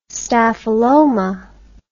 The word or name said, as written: staphyloma